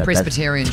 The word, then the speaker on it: Presbyterian: Presbyterian Church.